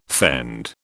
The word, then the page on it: fend: British English